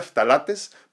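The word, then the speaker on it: phthalates: Entonces tenemos que evitar estos productos que contengan perfumes añadidos, eh, eh, se, escribe se pronuncia talates, pero se escribe ph talates, ¿no? ph talates, T-H-A-L-A-T-E-S.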